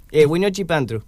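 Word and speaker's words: Wiñoy Xipantu: Y bueno, con motivo de la celebración del, vos decime si lo pronuncio bien, Huiñoixipantru. No, horrible, ¿cómo se dice? – Sí, así, Huiñoixipantru.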